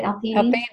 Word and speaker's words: L-theanine: L-theanine.